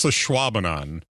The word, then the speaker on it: Ashwaubenon: It's a Schwabenon. But okay. If you look at the pronunciation of it, they make it look like ash. We say the A a little bit shorter as Ashwaubenon.